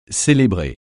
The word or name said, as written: célébrer